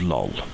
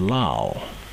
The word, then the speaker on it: Lal: I always thought it was Lol, but her name is spelt L-A-L, Lal. So it's possible it's Data's American accent. He says Lal, and I always thought it was Lol, but apparently it's Lal.